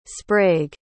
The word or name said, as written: sprague